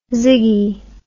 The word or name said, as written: ziggy